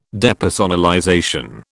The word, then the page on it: depersonalization: British English